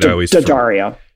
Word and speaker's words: D'Addario: So you led that, you did sell to D'Addario. I think I'm saying that right. – D'Addario. – I